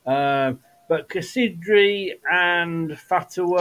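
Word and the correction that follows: Casadei: Cassidy. I will teach you to say his name right. It's Cassidy.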